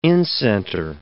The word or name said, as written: incenter